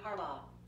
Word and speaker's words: Harbaugh: Baltimore Ravens coach is John Harbaugh. – Harbaugh.